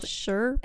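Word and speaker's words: Shurbutt: It's spelled Sherbutt. – S-H-U-R. Sure. – Sure. – But. – But. – B-U-T-T.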